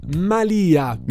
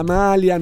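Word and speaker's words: malìa: La Malia di una strega, non la malia, non la maglia.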